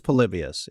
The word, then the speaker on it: Polybius: Polybius? – Polybius. – I've been saying Polybius. – I've heard that too before, but I think it's Polybius if we go kind of with maybe the Roman sounding word.